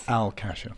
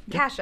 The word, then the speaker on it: Al Kasha: They had a couple readings of the musical in our illustrious producer and composer's apartment, Al Kasha, I'm saying that right, yes? – Al Kasha. – Kasha. Yep. Okay, cool.